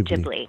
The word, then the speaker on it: Ghibli: So Ghibli, and it's Ghibli, correct? – Yes, that's how the filmmakers pronounce it, Studio Ghibli.